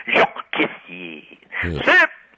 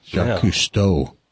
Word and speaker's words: Jacques Cousteau: Hey, once again with my French Jacques Cousteau. – Didn't even pronounce it right. Jacques Cousteau.